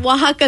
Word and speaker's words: Oaxaca: La Carta de Oaxaca? – Yes. – Wow. What? Stop saying that to me. I'm going to HR. – Oaxaca is a place in Mexico.